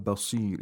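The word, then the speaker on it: البصير: The sad of basir is pronounced with the lowest level of tafkhim.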